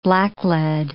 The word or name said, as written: blacklead